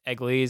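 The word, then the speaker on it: Eglee: I think it's Egli is how you pronounce his last name.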